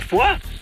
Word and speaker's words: Foix: Herr Sattelfest, man schreibt das zwar Foix, aber die Franzosen sagen Foix, so wie in Foie Gras. – Bist du vielleicht Franzose, Radio Lurch? – Nein, aber... – Kein Aber. Aber meinetwegen, ich frag mal diesen Franzosen. Hey, Franzmann, wie spricht man Foix? – Da spricht man vor, so wie im Vorgriff.